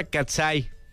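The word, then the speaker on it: Katseye: Se pronuncia Katsai.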